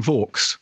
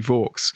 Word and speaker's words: Vaux: Well, the real life Anne Vaux, actually, we think she was called Anne Vaux. rhyming with forks.